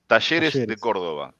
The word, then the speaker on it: Talleres: Sim, eu vi quanto ele jogando contra o Talheres. Uhum. É Talleres, como é que se fala? Como é que fala aí? – Talleres de Córdoba.